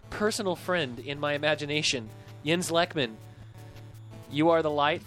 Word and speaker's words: Jens: I have actual Swedish friends that confirm that the name is pronounced Jens, but he pronounces it Jens as a sort of Anglophile because he's really influenced by like Scott Walker and Morrissey and bands like that.